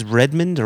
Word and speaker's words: Redmond: In Redmond, is it Redmond? – Yeah, Redmond. – Redmond. Yeah, I just didn't know if it was Redmond or Redmond. Like, how would you pronounce that? – Is there anywhere in the United States that puts that level of emphasis on the second syllable? – Oh, you know, it might be Redmond. I think that's what it is, Redmond. – Now you've got it, yeah.